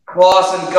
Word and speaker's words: Closson: Closson, golly, dude.